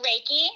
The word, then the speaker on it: Reiki: It's Reiki.